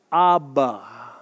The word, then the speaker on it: Abba: Any guesses what that root word is? Rhymes with Mabba. Abba. Abba.